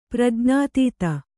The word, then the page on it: ಪ್ರಜ್ಞಾತೀತ: prajñātīta